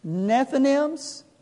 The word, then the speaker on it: Nethinims: They become known as the Nethinims, is another name they're known by. That's spelled N-E-T-H-I-N-I-M-S. N-E-T-H-I-N-I-M-S.